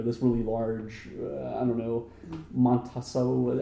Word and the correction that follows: Monsanto: How do you say that? Montatesso? – I have no idea. Monsanto? – Monsanto.